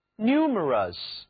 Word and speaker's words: numerous: 请注意 num 其中的字母 u 到了这里呢读作 u 而不再读三角 r, numerous。